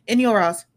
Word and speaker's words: Enjolras: Enjolras, I think. – Enjolras, close enough.